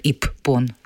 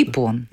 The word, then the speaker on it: Иппон: Иппон тоже его как бы не говорят Иппон, он как Понятно. Ипон.